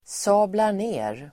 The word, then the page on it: sablar ner: [sa:blar_n'e:r]